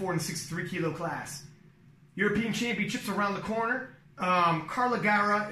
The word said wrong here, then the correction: Carola Gara: am I pronouncing that wrong? Is anybody? – It's Carola Gara.